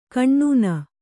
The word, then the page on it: ಕಣ್ಣೂನ: kaṇṇūna